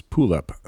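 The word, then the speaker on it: Puuluup: From Estonia, this is Pulup. This is, it's spelled P-W, as in two U's, P-U-U-L-U-U-P.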